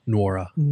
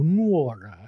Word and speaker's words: Nwora: Nwora. Yep. – So the N isn't silent. You just have to go Nwora.